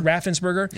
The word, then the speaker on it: Raffensperger: Raffensperger.